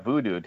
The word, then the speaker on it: Vudu: You got Voodoo, too. – Yeah, but that means something. – Not the way it's spelled here. – Oh, no. Oh, yeah, that's right. It's four letters. – V-U-D-U.